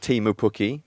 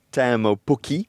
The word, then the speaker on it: Teemu Pukki: It's not Timo Pukki, Teemu Pukki.